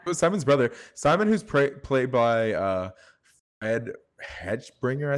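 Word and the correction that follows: Hechinger: I think his name is. How do you pronounce it? Let me hold on. Look. Yeah, his name is Fred Henschinger.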